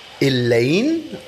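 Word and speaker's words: Eleine: die heißt Elaine, oder wie spricht man es aus? – Ja, ist richtig. – Das wird geschrieben E-L-E-I-N, was ist da hinten noch ein E?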